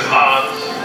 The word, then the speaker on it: Han: Does anyone else out here think his name is Hans? Oh, that drives me crazy! So many people say that. Listen up, and listen up good. It is not Hans Solo. It's pronounced... – Actually, it's pronounced Han.